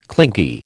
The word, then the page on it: clingy: American English